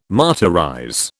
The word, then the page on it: martyrize: British English